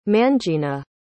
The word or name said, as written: mangena